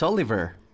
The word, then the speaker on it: Oliver: My name is Oliver. – Can you spell it? – O-L-I-V-E-R. Oliver. – What is your last name? – Brooks. B-R-O-O-K-S.